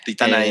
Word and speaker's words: Titane: Titán, nunca sé cómo pronunciarlo. – Titane. Eh, y creo que Titana, exacto.